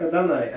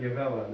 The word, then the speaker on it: Адонай: Да, но они-то знали, что это слово читается как Адонай, а не как Егова, да?